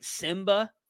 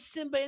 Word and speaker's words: Nsimba: I don't even know how to say this dude's name, but I'm pretty sure this is right. Simba it's spelled N S I M B A, so I'm assuming it's Simba and the N is silent.